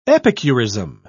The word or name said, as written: epicurism